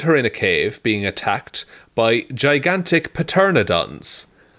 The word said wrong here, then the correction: pteranodons: I think it's actually pronounced pteranodons.